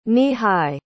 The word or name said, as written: knee-high